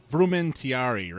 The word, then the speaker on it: Frumentarii: Yeah, well, I the Jesuits were very Roman, and I'm going to mispronounce this because my Latin is terrible, but it's the Frumentarii, right? Were the Roman secret police. – No, that was right. You got it right.